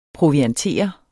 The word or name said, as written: proviantere